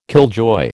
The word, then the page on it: killjoy: American English